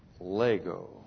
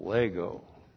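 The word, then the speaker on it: Lego (λέγω): And then we have Lego. L-E-G long O, Lego.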